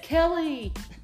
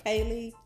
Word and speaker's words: Keleigh: It's Keleigh, right? they are called Kaylee, Kalai, Kelliga. When they try to pronounce every single letter, that's my favorite because that takes talent.